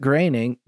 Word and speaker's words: Groening: Matt Groening, did I pronounce that right? – Yeah.